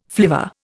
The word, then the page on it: flivver: Indian